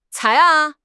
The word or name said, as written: cai2